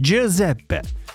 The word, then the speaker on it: Giuseppe: Giuseppe bildiğim kadarıyla G harfiyle yazılıyor. G ile yazılıyor. J olarak yani daha doğrusu J harfi. Giuseppe.